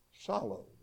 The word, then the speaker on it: psallo: P-S-A-L-L-O. Solo. The P is silent in the pronunciation.